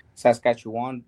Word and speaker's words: Saskatchewan: So, and like I said, all up in the north, I have friends from like, what is it, Greenland, Saskatchewan.